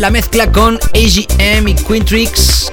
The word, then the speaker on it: Quintrix: Quintrix, se escribe Q-U-I-N-T-R-I-X.